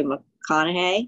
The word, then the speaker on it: McConaughey: Now I pronounced his last name wrong. Matthew McConaughey. Is that how you say it? Yeah.